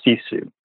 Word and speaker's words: sisu: There's a word that I learned from Top Gear a few years back, and it's a Finnish term, and it's pronounced Sisu. S-I-S-U.